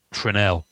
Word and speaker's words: Fresnel: So what's the correct pronunciation? – Fresnel. – Fresnel. – It's a French word.